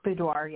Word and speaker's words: boudoir: Boudoir, yeah.